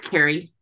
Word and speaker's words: Cari: And did I say that right, Kari? – It's Carrie.